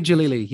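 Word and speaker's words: Djalili: Omid Dalili. – Omid Jalili.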